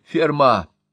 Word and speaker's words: Ферма: «Вам говорит что-нибудь имя Ферма?» «Ферма!» — ударение на последнем слоге.